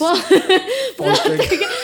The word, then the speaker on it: Poltergeist: The Plottergeist. Did I say that right? – Dude, I don't know how to say stuff. – Plottergeist. Poltergeist. – Poltergeist. I just moved one letter.